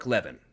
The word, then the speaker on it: Levin: With all that out of the way, Benedict, we continue our book review of American Marxism by Mark Levin. Levin. Fuck. I got his name right for so long and then I fucked it up right there.